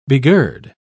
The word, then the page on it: begird: British English